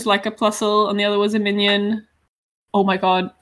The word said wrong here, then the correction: Minun: Is it pronounced like minion? – Not a minion, no. – No, it's Minun. I misread it. I've been pronouncing it minion in my head this whole time.